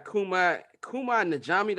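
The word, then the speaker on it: Kumail Nanjiani: We got Kumai Najami. That's how you pronounce his name.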